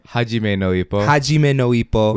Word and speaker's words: Hajime no Ippo: That's kind of like Ijen no Ippo. Am I saying that right? – Hajime no Ippo.